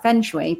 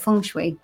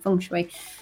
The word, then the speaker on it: feng shui: She is all about the, I always feel like I'm saying this wrong, feng shui, because it says like feng shui, but you say it feng shui, I think. That's how she said it anyway, feng shui. And all about feng shui in your office and your workspace, but also your life you went into, didn't she?